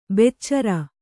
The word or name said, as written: ಬೆಚ್ಚರ